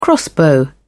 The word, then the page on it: crossbow: British English